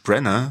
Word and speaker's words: Branagh: Einfach nur Kenneth Brenner. Brenner, so spricht man aus.